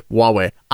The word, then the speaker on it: Huawei: Chinese phone maker Huawei, for its part, Huawei. I did not know for the longest time how to pronounce that. It's spelt Huawei. – Looks like Huawei.